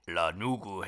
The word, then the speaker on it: lanugo: Lanugu hair. – Lanugu. I believe I'm pronouncing that correctly. It's spelt L-A-N-U-G-O, but it's pronounced lanugu.